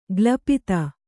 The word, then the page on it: ಗ್ಲಪಿತ: glapita